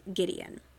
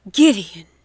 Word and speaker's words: Gideon: Now, the one person besides her brother that Cassidy can count on is her friend, Gideon. Oh, wait, I think I said that wrong. Her friend, Gideon. That's better.